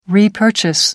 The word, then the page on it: repurchase: American English